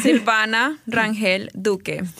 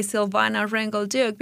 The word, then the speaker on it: Silvana Rangel Duque: No, I mean, like, when I introduce myself and I tell you, guys, this, I say Silvana Rangel Duque because if I say Silvana, people will be like, what? But if I say Silvana, people are like, oh, okay. – So to our ears. – I just try to make it easier.